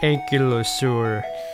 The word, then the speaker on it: ankylosaur: The word is pronounced ankylosaur.